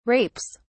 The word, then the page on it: rapes: British English